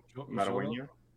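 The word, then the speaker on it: Xolo Maridueña: The actor's name is I don't want to mispronounce that. Zolo? – Jolo Marueña.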